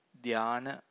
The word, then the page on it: dhyana: Pali